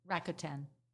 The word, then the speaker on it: Rakuten: Rakuten? – Rakuten.